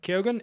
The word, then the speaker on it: Keoghan: Keoghan in Banshees of Inisherin.